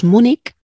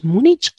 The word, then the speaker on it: Múnich: Entonces a veces parece como que nos suena mal, como que estamos diciendo algo incorrecto terminando en Múnich y decimos Múnich eso lo acepta la RAE. ¿eh? Las dos pronunciaciones, Múnich o Múnich.